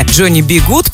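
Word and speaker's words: Johnny B. Goode: Кстати, полное имя Джонни Би Гуд произносится на английском, так же как фраза Джонни Би Гуд.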